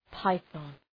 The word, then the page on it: python: ‘paıɵɒn